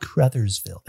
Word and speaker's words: Crothersville: Crothersville. – It was brother but with a C. Crother.